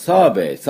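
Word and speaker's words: survey: サーベイって言いますね。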